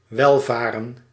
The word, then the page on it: welvaren: /ˈʋɛlˌvaːrə(n)/